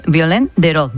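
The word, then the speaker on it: Violaine Bérot: Como bestias de Violaine Begot, ¿no? No sé si lo he dicho bien. – Sí.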